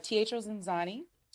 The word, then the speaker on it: Zanzani: You're in Chicago on a Teatro Ziazzini. – Did I pronounce that right? – Close, but it's Teatro Zanzani, and it is like love, chaos, comedy, and dinner, and not just that.